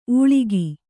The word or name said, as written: ಊಳಿಗಿ